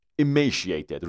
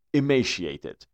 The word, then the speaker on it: emaciated: دوستان لغت اول میگه، دقت کنین، دو تلفظ داریم. emaciated روی ام تشدید هست. امه اینجوری میگیم emaciated یا میگیم emaciated. emaciated emaciated emay نمیگیم emay. اوکی emaciated emaciated.